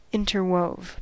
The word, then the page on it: interwove: American English